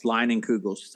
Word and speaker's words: Leinenkugel: So the first Shandy I ever had was Leinenkugel's Summer Shandy. Mm-hmm. Or Leinenkugel, I don't know if I'm pronouncing it right.